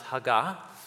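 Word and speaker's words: hagah (הָגָה): The word meditate is pronounced hagah in Hebrew, which is the language the poem was written in.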